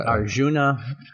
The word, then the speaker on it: Arjuna: Sure, I didn't say that right. – Arjuna. – Yeah, Susini. – Yeah. That's what I would have gone with.